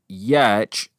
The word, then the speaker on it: Giertz: Giertz eventually started her own product design company called Yetch, spelled Y E T C H, phonetically spelling her last name.